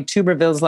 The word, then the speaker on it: Tuberville: Thank you for learning the correct pronunciation of Tommy Tuberville's last name. – Tuberville. – I know. Yeah, okay. Tuberville. Yeah, it's like tub of lard rather than tube.